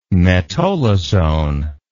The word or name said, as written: metolazone